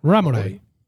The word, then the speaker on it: Ramoray: Joey ha saboreado fugazmente las miles del éxito al interpretar al doctor Drake, eh, Ramorey eh, Ramoray, no me acuerdo cómo se pronunciaba esto. – Ramoray.